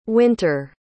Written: wynter

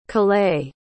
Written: callais

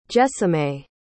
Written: Jesimae

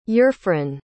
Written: eurfron

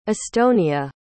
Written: estonia

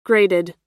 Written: grated

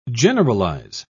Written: generalize

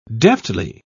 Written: deftly

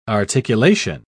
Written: articulation

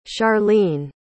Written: sharleen

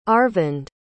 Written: Aravind

